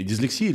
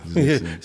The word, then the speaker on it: дислексия: Я, я как дислексик сразу задумался, а как правильно? – Да. – А, наверное, дислексия, да? Если... Дислексия. – Ир, дислексия. погуглить, пожалуйста, как правильно ударение ставить двум дислексикам.